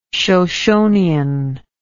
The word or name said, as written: shoshonean